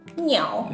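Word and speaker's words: Niao (牛): Niao. – Niao. Es como niao, se escribe, bueno. – Como, casi como un gato, pero con N. como niao.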